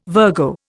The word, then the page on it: Virgo: Indian